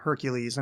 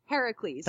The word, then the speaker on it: Heracles: Uh, the the Hercules, and I know it's what, how are you supposed to say it? – Heracles. – Thank you. I. It's just it's I pronounce it like Kevin Sorbo, and there's nothing I oh, can oh, yeah.